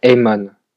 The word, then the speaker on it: Aemon: Et le deuxième Alors, si je me trompe pas, il y a un il y a un maître qui s'appelle Aemon. – Ok, Maître Aemon. En plus, tu l'as bien prononcé. Quand tu as un A et un E à côté, c'est ça se prononce E en fait.